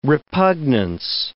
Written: repugnance

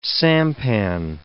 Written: sampan

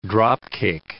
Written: drop-kick

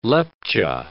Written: Lepcha

